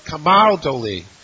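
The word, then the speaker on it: Camaldoli: Camal Dolese. And he told me how to pronounce it, so thank you for the assistance. Camal Dolese. I should have known Italian, the second accent, right?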